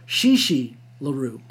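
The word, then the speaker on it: Chi Chi LaRue: Well, on the West Coast, he is approached by a famous pornographer, Chicha LaRue. I think I pronounced that wrong. Shishi LaRue.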